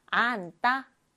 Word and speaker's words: 앉다: 안따. 겹받침, 받침, 겹받침, 니은, 지읒이 쓰였죠? 안따는요, 앞에 있는 자음, 니은이 남아서 안 이렇게 먼저 읽어요. 그 다음 다가 아니라 안따, 안따라고 읽어줍니다.